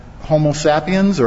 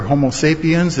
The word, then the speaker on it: Homo sapiens: So let's look a little closer at Homo sapiens, or Homo sapiens, it depends. You can pronounce it either way.